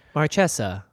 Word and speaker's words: Marchesa: I like the way they do it on the website. Marchesa. – Marchesa. That's how we originally pronounced it, and then we switched to Marquesa, and then we found out that Wizard wants us to pronounce it Marchesa.